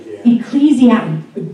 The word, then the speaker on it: Ecclesiastes: I cannot say this, Ecclesiastes. How do we say that? – Ecclesiastes. – Dude, I would not have gotten that. Ecclesiastes. Ecclesiastes. Ah, thank you.